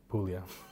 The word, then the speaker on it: Puglia: Rhymes with Shmulia. Puglia.